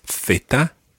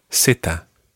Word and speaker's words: zeta: In Spain, it's Theta. – Theta. – And in Latin America, Zeta.